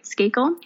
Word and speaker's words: Skakel: Skakel? – I mean, who is, sure.